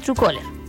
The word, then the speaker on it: Truecaller: como Truecaller. Ahí nos pueden preguntar al WhatsApp, oiga, señorita, ¿cuál es la aplicación? Se lo enviamos aquí el nombre. Se escribe Truecaller, pero ese es Truecaller.